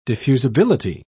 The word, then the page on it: diffusibility: British English